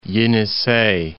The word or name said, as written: Yenisey